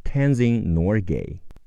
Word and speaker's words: Tenzin Norgay: Tenzin Norgay。